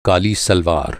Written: सलवार

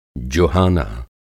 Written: जुहाना

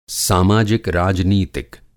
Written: सामाजिक-राजनीतिक

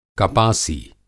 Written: कपासी

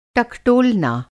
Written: टकटोलना

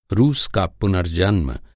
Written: पुनर्जन्म

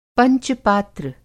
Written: पंच-पात्र

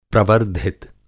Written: प्रवर्द्धित

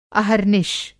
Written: अहर्निश

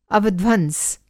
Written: अवध्वंस

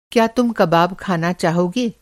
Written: कबाब

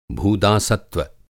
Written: भूदासत्व

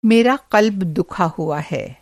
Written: क़ल्ब

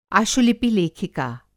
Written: आशुलिपि लेखिका

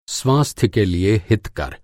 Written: हितकर

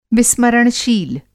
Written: विस्मरणशील

